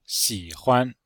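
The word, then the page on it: xihuan: xi3huan5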